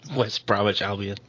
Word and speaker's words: West Bromwich Albion: West Bromwich Albion.